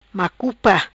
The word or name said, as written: Macupa